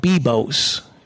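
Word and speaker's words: Biblos: I'm not going to talk a lot specifically about software, but there is one there, an awesome tool called Bibos. B-I-B-L-O-S. I think the L is silent.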